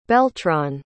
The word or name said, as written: beltran